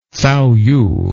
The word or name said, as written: Cao Yu